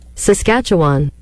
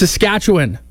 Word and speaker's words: Saskatchewan: Americans love saying this. – Saskatchewan. – Saskatchewan. It's Saskatchewan. But